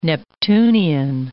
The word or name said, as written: Neptunian